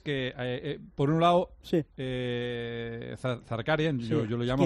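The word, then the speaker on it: Zakharyan: Cómo se pronuncia su apellido y es Zaharian. Este es la pronunciación correcta.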